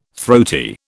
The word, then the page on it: throaty: British English